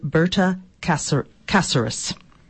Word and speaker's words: Berta Cáceres: Today, Simon will be discussing the crisis in Honduras and the events that led to the assassination of indigenous leader Berta Cáceres.